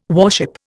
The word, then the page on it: warship: Indian